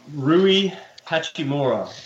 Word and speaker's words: Hachimura: Yeah, Rui Hachimura.